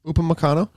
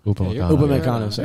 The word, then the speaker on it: Upamecano: Upamecano? – Upamecano.